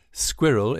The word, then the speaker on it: Skwirl: By the way, Skwirl is pronounced like the furry little critter, but spelled phonetically S K W I R L.